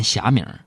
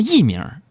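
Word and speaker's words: 佚名: 我想了半天，我跟这老板大哥就说：“ 我说哥，那个不念霞名，那叫艺名，就是找不着作者的意思。”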